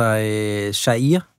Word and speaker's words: Jair: Altså, nu har vi fået besøg af dig, Christian Anblad, DR's Sydamerika-korrespondent, og jeg ved slet ikke, om jeg udtalte Jair Bolsonaros navn rigtigt, eller oversatte helt korrekt, hvad det var, Flavio her fik sagt i sin jiu-jitsu-dragt. – Jo, det tror jeg. Altså, Jair. Altså, Jair.